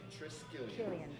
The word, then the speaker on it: Triskelion: Like a short I. – Yeah, Triskelion. – Okay. – Not Triskelion, Triskelion.